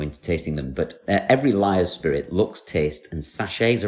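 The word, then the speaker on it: Lyre's: So the company's called Liars, which is spelled L-Y-R-E apostrophe S.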